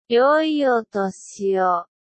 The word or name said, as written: yoi otoshi o